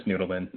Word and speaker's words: Nudelman: It's Nudelman.